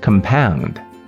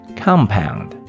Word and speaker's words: compound: As a verb, it is pronounced as compound. As a noun, it is pronounced as compound.